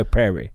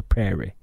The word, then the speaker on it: prairie: De sier vel prayer. De sier jo prairie. De sier ikke prayer. De sier prairie.